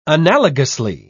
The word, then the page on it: analogously: British English